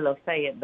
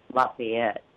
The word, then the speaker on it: Lafayette: We say Lafayette, but I think the correct pronunciation is Lafayette.